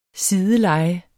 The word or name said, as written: sideleje